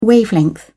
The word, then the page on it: wavelength: British English